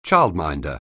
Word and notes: childminder: anglais

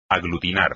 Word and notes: aglutinar: espagnol

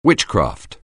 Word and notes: witchcraft: anglais

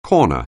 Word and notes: corner: anglais

